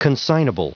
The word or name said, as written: consignable